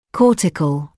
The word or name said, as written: cortical